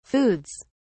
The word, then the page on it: foods: British English